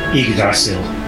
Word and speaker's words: Yggdrasil: Yggdrasil.